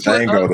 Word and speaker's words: Django: Like, y'all seen the Django? Like, we literally were the Django, the D is silent.